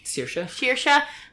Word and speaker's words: Saoirse: Sh- Saoirse.